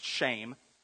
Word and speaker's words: shem: It's actually spelled S-H-E-M. It's pronounced shame.